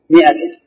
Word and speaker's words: مئة: مئة ولا ما؟ وش الفرق بينه؟ ما في جواب. كلمة مئة فيها ألف تكتب ألف لكن ما ينطق بها.